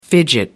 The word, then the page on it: fidget: American English